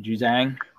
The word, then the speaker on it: Juzang: Juzang? – Juzang, yeah.